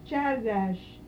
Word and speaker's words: Csárdás: C-S-A-R-D? – Csárdás.